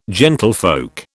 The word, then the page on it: gentlefolk: British English